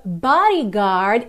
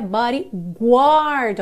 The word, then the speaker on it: Bodyguard: Tema do filme O Guarda-Costas, que em inglês é The Bodyguard, e atenção que não é bodyguard, ok? Você não pronuncia essa letra U que aparece aí. Então a pronúncia correta é The Bodyguard, ok?